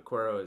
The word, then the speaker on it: Cuero: Cuero, Texas.